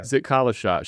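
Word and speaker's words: Zitkala-Ša: Then, like almost immediately after I got to this part in your book, a woman named Zitkalasa, is that how you say it? – Zitkalasa.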